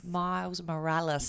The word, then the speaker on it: Miles Morales: Is that how you say it? – Miles Morales. – You've got to put the little Puerto Rican on it.